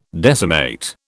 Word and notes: decimate: British English

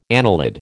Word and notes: annelid: American English

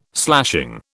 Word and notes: slashing: British English

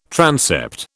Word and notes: transept: British English

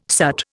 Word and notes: soot: Indian

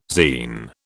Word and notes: zein: British English

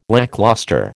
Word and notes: lacklustre: American English

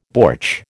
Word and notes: bortsch: American English

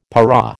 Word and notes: para: American English

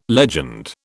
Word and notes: legend: British English

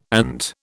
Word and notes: ant: British English

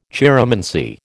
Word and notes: chiromancy: American English

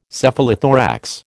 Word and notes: Cephalothorax: American English

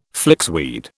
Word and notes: flixweed: British English

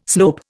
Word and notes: slope: Indian

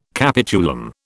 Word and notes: capitulum: British English

